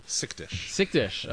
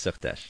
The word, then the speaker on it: Sychterz: Can you pronounce that one more time for Sychterz. Me? Sychterz. Thomas Sychterz. – Sychterz. Okay. – That's right. – Tell us about what you do and about Wipebook. – Well, Wipebook, W-I-P-E book, is the first ever erasable whiteboard notebook.